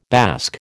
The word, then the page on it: bask: American English